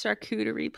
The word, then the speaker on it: charcuterie: It's not a character board. – It's a character board without the character. – It's charcuterie, please. Say it right. Can you please just say that right? – I'm not saying it right.